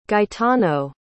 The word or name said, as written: Gaetano